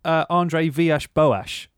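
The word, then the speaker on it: André Villas-Boas: So it's about Andre Vyash Boas.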